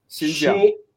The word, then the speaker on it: Xinjiang: Xinjiang.